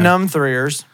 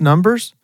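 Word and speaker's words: Numb3rs: He was in Numb-Threers. He was in that show. – What? – You ever never watched Numb-Threers? – Numb-Threers? – Yeah. I think it's pronounced Numbers, But it's spelled like num threers.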